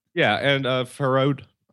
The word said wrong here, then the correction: Farhad: God, I always pronounce his name wrong because, again, I only just read it. Farhad Manju, who is a tech writer who I actually really like.